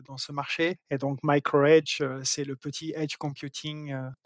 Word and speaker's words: MicroEJ: Comment est-ce qu'on prononce correctement MicroAge alors oui, effectivement, euh, MicroEdge se prononce, enfin s'écrit micro e euh, et effectivement, euh, on l'appelle MicroEdge, version anglaise, parce qu'on joue un peu sur les mots.